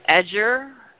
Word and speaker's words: adjure: Let's see, ajar, not pronouncing this right. Adjure. A D is in David, J U R E. Adjure, which means to request earnestly, to make supplication, petition.